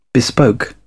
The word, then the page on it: bespoke: British English